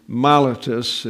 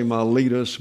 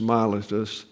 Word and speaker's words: Miletus: From Miletus, or Miletus is the way it's pronounced in the Koine Greek. We say Miletus, but it's Miletus.